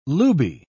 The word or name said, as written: looby